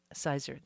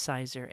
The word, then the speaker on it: cyser: So C-Y-S-E-R, sizer. That's how I spell it. Some people throw in a Z too. But anyway, you say it, it's sizer.